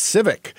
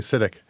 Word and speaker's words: CITIC: You know, you write about this one scene in a courtroom where the judge is like, "What's Civic?" He's talking about a company that's bigger than Microsoft, and he mispronounces it. He thinks it's called Civic, and somebody has to say, "No, it's actually CITIC."